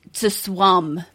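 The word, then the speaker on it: ZA/UM: John says it's pronounced to swum. To swum that ZA, the Disco Elysium developer.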